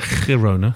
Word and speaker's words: Girona: I think earlier in the week when he played against Girona, how we pronounce it? – Girona.